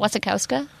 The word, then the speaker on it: Wasikowska: Wasikowska, I think. I think you're having an extra K in there. I think it's Wasikowska.